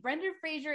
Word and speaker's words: Brendan Fraser: Brendan Fraser is bi. Okay. I'm sorry. – I agree. I mean, I was impressed that you said his name correctly.